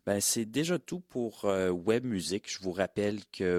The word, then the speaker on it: Ouèbe: Le web s'écrit O-U-E-B-E.